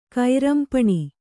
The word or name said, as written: ಕೈರಂಪಣಿ